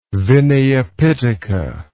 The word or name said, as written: Vinaya Pitaka